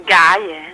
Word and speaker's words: Gaye: Ma dimmi una cosa, è da molto ma tutto qui, Marvin Gaye. – Sì. – Gaye. Marvin Gaye. – Fai finta. Si legge Gaye. – Si dice gay. – Gay. – Gay, proprio gay.